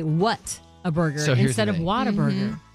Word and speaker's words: Whataburger: They say, what a burger, Whataburger. It's pronounced Whataburger.